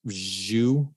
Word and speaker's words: Zhu: Zhu, I believe it's how it's pronounced, Z-H-U.